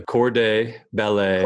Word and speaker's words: corps de ballet: So the corps de ballet. – Corps de ballet. – Did I say that right? – Yeah. Your French is impeccable.